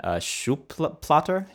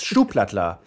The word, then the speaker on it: Schuhplattler: Yes, I read, I was reading about you, and it said you started your career as a, I'm going to butcher the name here, a German Schuhplatter? – Yeah, that's very close, yeah. – Schuhplatter? – That's it. That's okay. Right. Yes, Schuhplattler.